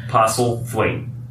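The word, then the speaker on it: Postlethwaite: Peter Postlewaite. – Postlewaite.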